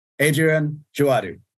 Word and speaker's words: Adrien Djouadou: And today I am here with the maestro composer behind Phoebus the Night and Hartlight, Adrien, Djouadou.